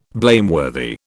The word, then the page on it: blameworthy: British English